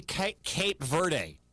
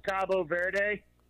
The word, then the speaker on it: Cabo Verde: They in Spanish, it's Cabo Verde. – Cabo Verde. – Cabo San Lucas or whatever in Mexico. Cabo Verde. But okay. – The English pronunciation is Cape Verde.